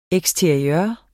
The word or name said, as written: eksteriør